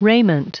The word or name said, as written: raiment